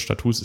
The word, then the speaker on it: Status: Allein die Status, die wir drücken, die, ich weiß übrigens, wie Status ausgesprochen wird, Status ist die Mehrzahl.